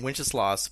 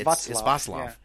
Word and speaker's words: Václav: I mean, that's a hard thing to, for saying Wenceslaus for most Western people. That's not how you say it here in Czech. – It's Václav.